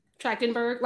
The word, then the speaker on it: Trachtenberg: How do you, how do you pronounce it? – Trachtenberg, like Michelle. Trachtenberg.